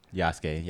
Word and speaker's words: Yasuke: It's called, you correct me on how to pronounce it, Yusuke? – Yasuke. Yasuke's trash, but okay.